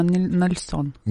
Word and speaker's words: Нельсон: Ну, мне кажется, извините, ударение на Нельсон. – Нельсон, извините, да.